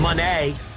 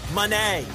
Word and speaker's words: Monet: Monet. Monet. Not even, it's not money. – Monet. – It's not Monet. It's Monet.